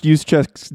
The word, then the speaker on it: Juszczyk: I love how you pronounced Juszczyk's names correctly and then took the opportunity to then mispronounce it and then just moved on.